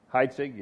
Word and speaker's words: Heitzig: This teaching is from the series Fight for the House by Skip, I guess he pronounced his name, Heitzig. Is that right? Heitzig, yeah, okay.